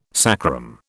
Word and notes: sacrum: British English

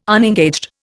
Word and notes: unengaged: Indian

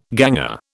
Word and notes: ganger: British English

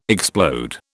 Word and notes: explode: British English

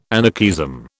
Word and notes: anarchism: British English

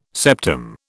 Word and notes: septum: British English

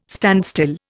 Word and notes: standstill: Indian

